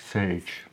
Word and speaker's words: Sage: Ja, Sage vind ik moeilijk uitspreken. – Sage.